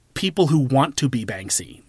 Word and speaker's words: Banksy: I'm going to compare Jackass to Banksy. – Okay. – And here's why. Because, did I say his name right? – Yes.